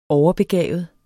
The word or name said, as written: overbegavet